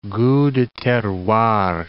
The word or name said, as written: gout de terroir